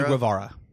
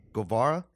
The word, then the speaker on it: Guevara: Sammy, I always mispronounce his last name. – Sammy Guevara. – Guevara? – Yeah, we're from Texas. You should be able to pronounce Hispanic last names.